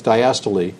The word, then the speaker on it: diastole: but it's diastole. There's systole and diastole. Well, it's like why you go to med school. Right? When you read it, it seems to be pronounced the way you said it.